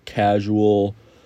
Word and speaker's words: Kazual: But after that, they had this a cappella singing group called Casual Mania come in. And it's spelled interesting. It's spelled like Kazool. It's like K-A-Z-U-A-L.